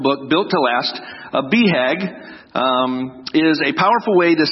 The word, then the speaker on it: BHAG: And one of the terms that he introduced us to in this book was, we pronounce it BHAG, but it's B-H-A-G, Big Hairy Audacious Goal.